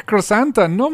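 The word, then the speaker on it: Krrsantan: Black crossanta no manches. Sí, llegó Black, que por cierto ya, ya sabemos cómo se pronuncia. Crasantan. Crossantan, exacto.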